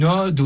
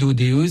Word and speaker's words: ode: Une ode, ODE, ça se dit avec un O ouvert, et pas une ode, comme on entend dans le film, comme le prénom, comme si c'était un O fermé.